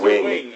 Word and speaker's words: Wing: And the old shop owner, Mr. Wong, is like, no, there is no responsibility. Did I say his name wrong again? – Yeah, he did. – What is it? – Wing. Mr. Wing.